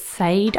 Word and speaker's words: Sade: Sade, it's Sade, actually.